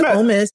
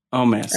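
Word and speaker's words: Omos: I always mess up how I say his name, but Amos, who's the big man who came in and interfered. I still can't, Mari. Omis, or is it? – I think it's Omis. – Omis.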